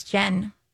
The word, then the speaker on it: Gen: My middle name is your middle name without the L. – Gen? – Yeah, it's Jen. – Okay, but is it spelled with a G? – Mm-hmm.